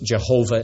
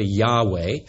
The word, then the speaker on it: Yahweh: We're used to pronouncing it Jehovah at times, but it's really Yahweh.